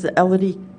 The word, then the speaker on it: Elodie: My name is Elodie Cossette, E-L-O-D-I-E, Cossette, C-O-S-S-E-T-T-E.